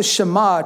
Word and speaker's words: Shema: Because the first word here in Hebrew is Shema. That's how you pronounce it.